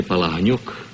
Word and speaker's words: Palahniuk: Actually, my name is Vladimir Palahniuk, but... – Oh, why didn't you tell me? – I pronounce it Jack Palance. – Palance. – Mm-hmm. How did you happen to pick that name? – Jack Palance? – Yeah. – Oh, it was sort of one of those things, Palahniuk. Nobody could pronounce it. They said, well, we'll call you Palansky, and from Palansky I took Palance. But the name is Palahniuk.